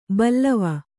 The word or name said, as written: ಬಲ್ಲವ